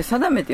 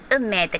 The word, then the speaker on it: 定め: 運命って書いて定めって読むんですけどみんなお客さんは 9 割方王の運命、王の運命ってみんな言ってきはるんでもう正さない、はいって言ってチケット出ますけど、王の定めが正しい読み方、運命と書いて定めなんですけど、それがね、この王の涙、遺産の決断っていうシネヌーボー X さんで上映されるお話の前のお話になるんです。